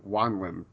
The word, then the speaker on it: Wanlim: And we have a special guest on this episode, the big bad for season four, Jessalyn Wanlim.